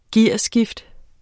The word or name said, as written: gearskift